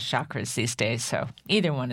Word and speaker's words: chakras: Is it chakras or chakras? – The proper way to pronounce it is chakras, like the hard CH, like church.